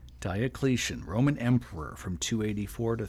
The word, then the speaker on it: Diocletian: Diocletian. – Diocletian, proclaimed emperor by armies of the East and West after the death of Carinus.